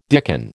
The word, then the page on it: dicken: American English